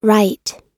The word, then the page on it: right: American English